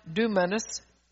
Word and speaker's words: dunamis: The dunamis, pronounced dunamis, power of God, is what they carry because it is what is needed for this time.